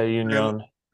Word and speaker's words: Union: And this season, though, we're seeing crosstown rivals, Union, I think I'm saying it right. – Yeah, Union.